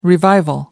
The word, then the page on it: revival: American English